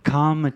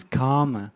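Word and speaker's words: kāma: Now, this sensual desire, it's in Pali it means karma chanda. Karma, it's K long A-M-A, not K-A-R-M-A or K-A-M-M-A.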